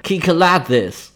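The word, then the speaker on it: Kiklades (Κυκλάδες): 整个词儿读起来呢是 Kiklades，读音是介于基克拉底斯和基克拉泽斯中间，所以往两边翻呢都还说得过去。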